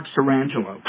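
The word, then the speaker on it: Sirangelo: And so I hope, what is it, Mike Strangolio? I might have. – Oh, Mark Sorangelo. – Sorangelo, thank you.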